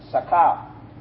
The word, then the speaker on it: Sakel: If you're writing down, Sakal is spelled S-A-K-E-L. Sakal.